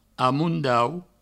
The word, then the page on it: amondaut: amoundaut